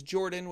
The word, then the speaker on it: Jorphdan: My name is Jordan with a silent PH in the middle, and I am joined always by my wonderful co-host, Sir Lucian, over at Sir Lucian Gaming.